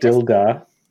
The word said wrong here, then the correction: Sildar: My name is Sildar, not Dildar.